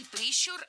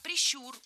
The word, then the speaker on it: прищур: Не прищур, а прищур. Ударение в этом слове ставим на второй слог. Да там его и оставляем во всех формах. Только прищур, прищура, прищуре.